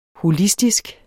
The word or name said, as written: holistisk